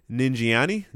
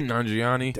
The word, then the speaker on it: Nanjiani: Directed by Chloe Zhao, Kumail Nanjiani. Did I finally pronounce his name right? – Nanjiani.